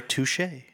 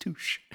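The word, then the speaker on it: touché: Of Ben Stiller, touche. – Actually, it's pronounced touche.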